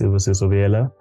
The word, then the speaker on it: Sibusiso Biyela: As you so well pronounced, my name is Sibusiso Biyela.